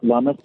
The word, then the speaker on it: Lummis: is it Loomis or Lummis? – We pronounce it Lummis, I believe is the correct way.